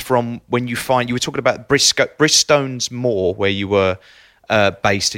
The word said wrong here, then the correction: Bridestones: So it's pronounced Bridestones. – Bridestones, sorry.